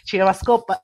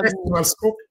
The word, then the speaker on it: CinemaScope: CinemaScope e Shift 72, e stavolta l'ho sì. detto giusto. – CinemaScope comunque viene così.